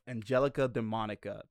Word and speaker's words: Angelica Demonica: he is the author of Angelica Demonica. Did I say that right? – Yes, you did.